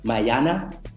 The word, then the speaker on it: Mayana: You talk about the record Mayana, that's how I pronounce it, right? – Yeah.